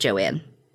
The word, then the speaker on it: Joan: I will say, her mom's name is spelled Joan, but one thing said it was pronounced Joanne.